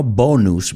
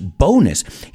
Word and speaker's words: bonus: He took his bonus, vamos a pronunciar esa palabra, bonus, no bonus, bonus.